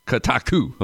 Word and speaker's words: Kotaku: And he's here to talk about the Gizmodo Media Group Union, which covers workers at Gizmodo, The Root, Kotako, I believe that's how you pronounce it, Jezebel, Lifehacker, and Jalopnik. Did I get those names correct? Tell me. Help me out here. – Yeah. Kotaku. But yeah, otherwise great. – Kotaku. Okay, real good.